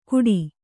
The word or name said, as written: ಘುಟಿ